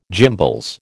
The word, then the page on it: gimbals: American English